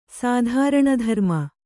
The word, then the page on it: ಸಾಧಾರಣಧರ್ಮ: sādhāraṇa dharma